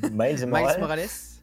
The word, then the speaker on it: Miles Morales: 6,5 millions de copies de Spider-Man euh, Mille Morales. Miles Morales. je crois. Miles Morales. prononcer les S.